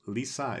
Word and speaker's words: Lise: Sorry, it's Lisa is how they keep pronouncing it. I'm reading it because it's spelled L-I-S-E, the French spelling.